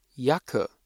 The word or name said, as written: Jacke